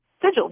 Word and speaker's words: sigil: You've got a workout that is called the Power Sigil, or how do you pronounce that? Sigil? – Sigil, yes. You got it on the first track.